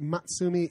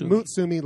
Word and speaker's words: Mutsumi: He says it's not Matsumi. It's Mutsumi, like the cow that she does not resemble at all.